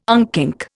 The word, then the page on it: unkink: Indian